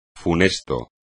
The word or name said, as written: funesto